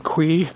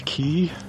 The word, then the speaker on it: Quy: Q-U-Y is apparently pronounced qui and not key.